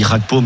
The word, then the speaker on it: Gakpo: On dit Cody Gakpo ? – On dit Gakpo, mais j'allais pas le.